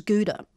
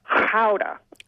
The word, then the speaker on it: Gouda: Or as I've seen the pronunciation guidelines, Gouda. – Okay. – I don't say it like that myself. I do go with the Gouda, but Wikipedia was saying that the Dutch like the Gouda. – They've got the hard H's, don't they?